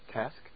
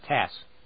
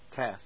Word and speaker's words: TASS: It's a single syllable, and it begins with T. – Task? – TASS. – TASS. – TASS. T-A-S-S.